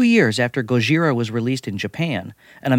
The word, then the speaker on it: Gojira: That original 1954 Japanese film, spelled differently and pronounced Gojira, came less than 10 years after America dropped atomic bombs on Hiroshima and Nagasaki.